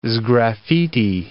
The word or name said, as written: sgraffiti